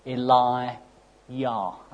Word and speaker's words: Elijah: John, as you remember, confronted King Herod, And here in 1 Kings 17, we see Elijah confronting King Ahab. The name Elijah, by the way, can be split into two parts. Eli-yah, as it's pronounced in Hebrew.